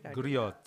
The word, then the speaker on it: Griot: Griot.